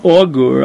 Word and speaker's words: augur: What's the case of a Menachesh, which I see that the English translation translates this as Augur. I'm not pronouncing that right. A-U-G. You are,